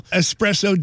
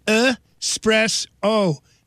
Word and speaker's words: espresso: Today is National Espresso Day, celebrating that Italian coffee drink that keeps people buzzed most of the day, and it is espresso. Not espresso. Say it right. Espresso. Espresso. By the way, 63 milligrams of caffeine in an espresso compared to about 40 in your regular cup of coffee.